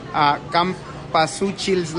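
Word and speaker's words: cempasúchil: And then the other featured cocktail is Campuchili's Lover. Campasuchil's Lover, sorry, I said that wrong.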